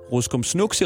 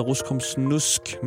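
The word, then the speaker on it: Ruskomsnusk: Og øh, i forgårs sagde jeg Ruskum Snuks i radioen, og det kan man bare ikke. Det hedder Ruskum Snusk, men det er et af de ord, som øh, min mund har svært ved at udtale.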